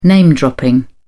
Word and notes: name dropping: British English

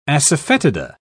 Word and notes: asafetida: British English

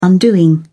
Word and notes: undoing: British English

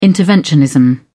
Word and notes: interventionism: British English

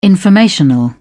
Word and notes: informational: British English

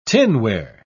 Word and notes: tinware: British English

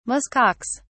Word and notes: musk ox: British English

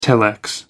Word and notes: telex: American English

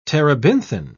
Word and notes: terebinthine: British English

